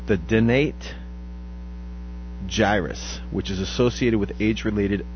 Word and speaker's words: dentate gyrus: And are you sure that's how you say it? – Dentate. Dentate. Dentate gyrus.